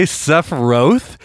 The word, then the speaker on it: Sephiroth: In the game, the first time they say Sephiroth, they say Sephiroth, and I almost threw my controller. I was like, I've been saying it wrong this whole time!